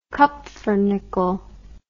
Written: kupfernickel

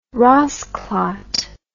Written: rassclaat

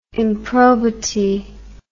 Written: improbity

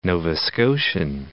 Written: Nova Scotian

